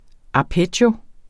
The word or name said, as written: arpeggio